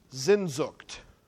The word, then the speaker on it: Sehnsucht: And the German word, I think, is pronounced Sehnsucht. S-E-H-N-S-U-C-H-T, for you scrupulous note takers.